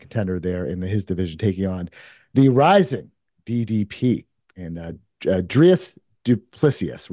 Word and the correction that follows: Dricus du Plessis: But did I pronounce that right? – Dreykus Duplessis. – Dricus du Plessis, DDP.